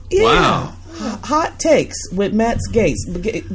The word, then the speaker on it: Gaetz: And we're just going to jump right into politics, starting with Mr. Gaetz.